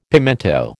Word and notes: pimento: American English

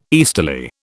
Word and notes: easterly: British English

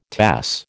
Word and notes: Tass: American English

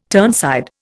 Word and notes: turnside: Indian

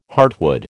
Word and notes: heartwood: American English

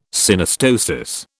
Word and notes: Synostosis: British English

